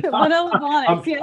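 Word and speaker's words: Manolo Blahnik: One thing that I love that you wrote in your book is you talked about your Manola Blahnik. Did I say that right? – Manola Blahnik.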